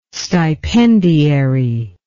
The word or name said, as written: stipendiary